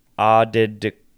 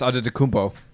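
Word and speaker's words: Antetokounmpo: So and then it says that so his last name is Nigerian, so the T's in his name should be pronounced as D's. This would make the pronunciation Adedikumpo. – Giannis Adedikumpo.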